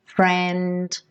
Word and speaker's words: friend: Nessa canção, a gente tem várias vezes a letra T e a letra D, que são consoantes oclusivas, a gente consegue vê-las pintadinhas de vermelho, precedidas de letra N. Can't, diamond, friend. E quando isso acontece, é possível que essas consoantes oclusivas não sejam pronunciadas, praticamente não apareçam.